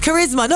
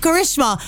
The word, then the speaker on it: Karisma: It's Karisma, not Karishma. I've grown up saying Karishma.